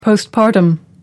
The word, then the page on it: postpartum: American English